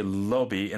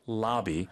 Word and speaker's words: lobby: So you can say lobby in the British pronunciation or lobby in the North American pronunciation.